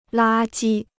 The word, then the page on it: 垃圾: lā jī